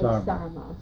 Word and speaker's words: sarma: to make sarma. – Sarma.